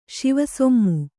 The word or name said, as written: ಶಿವಸೊಮ್ಮು